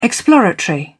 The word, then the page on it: exploratory: British English